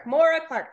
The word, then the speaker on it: Moira Clark: Moira Clark.